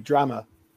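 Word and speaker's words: Drameh: And how's, I think it's Jerema, his pronunciation could be wrong. How's he doing on loan from Leeds? – So it's Cody Drama.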